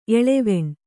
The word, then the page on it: ಎಳವೆಣ್: kannada